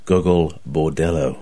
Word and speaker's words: Gogol Bordello: Gogol Bordello.